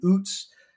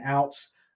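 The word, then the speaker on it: Ouzts: And apparently I've been mispronouncing his name all season, which I'm prone to do. But Robbie Oots, I've been saying outs. It's Robbie Oots, had 1 for 8, and that was his first reception.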